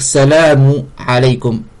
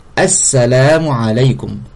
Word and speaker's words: Assalamu alaykum: Parmi ces conditions, on prononce l'article défini. Donc ça veut dire le A au début. Ainsi, il ne suffit pas de dire Salamu alaykum. Il faut bien l'article défini, le alif lam.